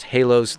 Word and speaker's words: HÆLOS: This is the band HÆLOS, spelled H-A-E, and the A and the E are crunched up together, H-A-E-L-O-S, pronounced HÆLOS.